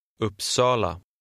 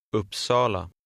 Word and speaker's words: Uppsala: The stress is on the second syllable. – Uppsala. Uppsala.